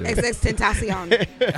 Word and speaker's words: XXXTentacion: Not extension. Is that how you've been saying it? – I thought that's how he calls himself. – No, it's XXXTentacion.